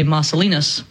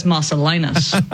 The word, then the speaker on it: Marcellinus: Well, we could go full ecclesiastical Latin and only pronounce names in that way and say Marcellinus. We could do that. But then people are going to be like, what's going on? What's this name? What's the Italian thing? So a lot of times when we're just speaking in English, I go with one of the English pronunciations, at least, of these Latin names, like Marcellinus, Marcellinus.